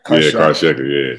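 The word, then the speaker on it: Karşıyaka: Karshiaka, is that how you pronounce it? – Karshiaka, yeah.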